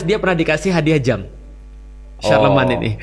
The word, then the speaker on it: Charlemagne: Oh, Charlemagne bacanya apa? – Charlemagne. – Oh, Charlemagne. – Charlemagne.